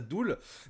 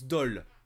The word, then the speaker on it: Dool: Mais, sachez, que je pense que tout pendant toute notre paragraphe sur Doul, on va dire, on va prononcer ça Doul, mais il faut savoir que ça se prononce Dol.